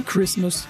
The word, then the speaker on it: Christmas: Mais Christmas, sans prononcer le T.